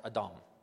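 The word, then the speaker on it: Adam (אדום): Now in Hebrew, red is pronounced Adam, Adam.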